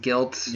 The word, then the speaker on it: GYLT: The other one was GYLT. I think it's pronounced Guilt.